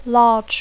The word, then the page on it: large: English